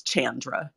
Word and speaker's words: Chandra: It's Chandra. So like Jackie Chan.